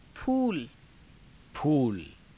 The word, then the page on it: phuula: Hindi